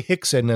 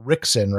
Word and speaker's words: Rickson: So we say Hickson, and that's the Portuguese pronunciation. It's spelled Rickson, right? – Yeah.